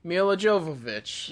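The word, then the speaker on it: Milla Jovovich: I'm picking, and I probably say her name wrong, Mila Jovovich.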